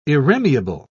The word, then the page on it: irremeable: British English